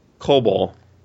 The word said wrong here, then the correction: Kobold: Kobold. – Is it kobold? – Kobold. KOBOLD. Like yeah.